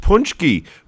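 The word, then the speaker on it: pączki: Yeah, well, it's actually called, it's pronounced punchki, but it's spelled, if you can't Google it because it's spelled like an eye chart.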